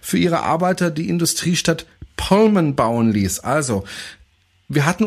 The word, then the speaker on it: Pullman: George Mortimer Pullman, ich sage jetzt immer Pullman, ah, ich hatte ja Pullman, oder wie, wie, wie muss ich das aussprechen? Pullman. Pullman. Pullman. Oder du sprichst es britisch aus, das ist ein Pullman.